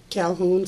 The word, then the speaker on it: Calhoun: Ora, O-R-A, Calhoun, C-A-L-H-O-U-N.